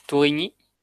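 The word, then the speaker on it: Tourigny: Y es un juego que el diseñador es Ips Turni. ¿Cómo se dice esto? – Pues Tuñi. Tuñi.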